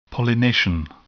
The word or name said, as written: pollination